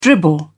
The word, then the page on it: dribble: British English